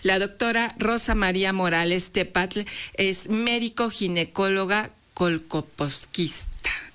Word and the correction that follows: colposcopista: No, colposquista. Bueno, discúlpeme usted, pero bueno, hoy... estoy medio trabada. Colposcopista. – Así es. – Ya lo dije bien.